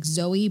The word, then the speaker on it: ZO-E3: As a participant of the experience, you're performing as a captured smuggler whose sidekick is a droid co-pilot, I think it's pronounced like Zoe, but Z-O-E-3, voiced by Maya Rudolph.